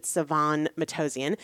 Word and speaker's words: Sevan Matossian: Uh, his name is Seven Matossian, uh, Sevan, actually, I think is how you pronounce it, Sevan Matossian.